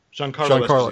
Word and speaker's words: Giancarlo: Now, and you're going to have to remind me the pronunciation of his first name, Giacascio Esposito. – Giancarlo Esposito.